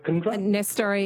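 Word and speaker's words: Nestory Irankunda: Nestori Irankundra? – Yep. Nestori Irankundra.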